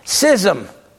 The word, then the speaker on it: schism: Schism, and that is the proper pronunciation, is formal and willful separation from the unity of the church.